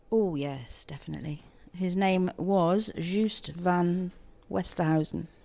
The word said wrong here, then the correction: van der Westhuizen: Van der Westhuizen? – Yeah. I could never really pronounce it, but I spent most of my time looking at him, not worrying about his name.